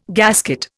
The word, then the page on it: gasket: Indian